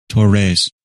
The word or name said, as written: Torres